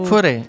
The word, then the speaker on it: Fauré: Fauré, estamos en España, vale. E se hacemos un pouco españolizado, non pasa nada. – Vale, Fauré, de músicos como Chabrier e Satie.